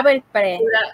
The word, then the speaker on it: Belpré: Beltrés, a ver si lo digo bien, porque ese, apellido es... Ah, este, ah, pura, Belpré.